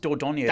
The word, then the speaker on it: Dordogne: I think it's pronounced Dordogne. It's like Dordogne.